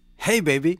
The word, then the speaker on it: Jei Beibi: It's J-E-I, that's the first word, and then the second word is B-E-I-B-I. Okay, so J-E-I-B-E-I-B-I. How do you say this album title? – Hey, baby. – So it's a little bilingual joke. It's a joke for the English-speaking listeners.